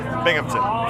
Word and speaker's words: Binghamton: Is that right? – Binghamton. – Bimington. How do you say it? – Binghamton. Yeah. – Oh, I spelled it wrong. How do you spell it? – B-I-N-G. – Oh. – H-A-M. – Oh. Why didn't you tell me? – Sorry, man. – Binghamton. – Yeah, Binghamton.